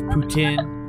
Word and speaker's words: poutine: I believe it's pronounced poutine. Okay. I too am Canadian. It is poutine.